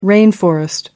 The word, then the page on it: rainforest: American English